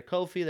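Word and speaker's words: Coffey: Let me make sure I got his name right. Amir Kofi, there we go.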